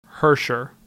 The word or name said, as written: Herscher